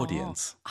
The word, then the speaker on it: audience: 영국 발음은 이 AU가 O 발음이거든요. – 오. – 오디오, 오디, 어. – 오디언스.